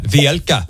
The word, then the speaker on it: Vielka: Is he saying your name correctly? – Of course. Come on, I'm nailing that. Vielka. – Yes.